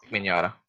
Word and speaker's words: Mignogna: Vic Mignogna, okay? I think I said that correct. – Mm-hmm. – Yeah. – Mignogna. – Wow, I actually said it correctly.